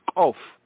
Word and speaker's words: Koph: Over here at the 145th verse, they have K-O-P-H. This letter properly pronounced is Kof, Kof, Kof, is Kof. Some of them, the Muslims and the Arab speakers, they say Kof, Kof, Kof, Kof. They pronounce it like almost like it's a hard G sound, Kof.